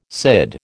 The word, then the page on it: CID: American English